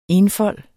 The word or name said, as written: enfold